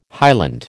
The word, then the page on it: highland: American English